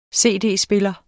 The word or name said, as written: cd-spiller